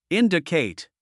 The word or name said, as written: indicate